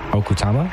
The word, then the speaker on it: Okutama: So, you know, just to make sure I got the name right, it's Okutama? – Mm-hmm, right.